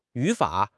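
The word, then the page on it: yu3 fa3: tones 3, 3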